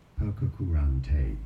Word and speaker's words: Pococurante: English pronunciation, Pococurante.